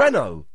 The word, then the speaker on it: Renault: They say the T, but it's French. So you wouldn't say the T in Renault. It's no. Renault.